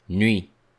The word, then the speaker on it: nuit: Notice that we don't pronounce the letter T. Nuit.